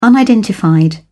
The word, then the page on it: unidentified: British English